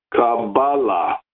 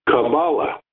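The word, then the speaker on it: Kabbalah (קבלה): As a general rule, in Hebrew, the second syllable is emphasized, Kabbalah, whereas in English, the first syllable is generally emphasized, Kabbalah.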